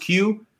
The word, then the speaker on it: KYU: It's spelled KYU, but it's pronounced Q.